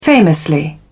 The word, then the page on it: famously: British English